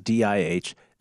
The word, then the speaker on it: DeJounte: DiHJHON-TAY. I actually give you the pronunciation. – And I have one that says DiHZHAHN. Either way, it's correct.